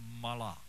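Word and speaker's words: malak (מלאך): In the Hebrew text, it is something that is pronounced like this: malak.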